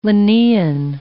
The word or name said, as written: Linnean